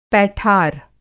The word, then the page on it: पैठार: Hindi